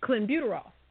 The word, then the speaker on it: clenbuterol: And Francisco believes he tested positive due to ingesting contaminated meat in Mexico, where they use clenbuterol. Is that how you say it? Do you know how you say that? – Clenbuterol. – Clenbuterol.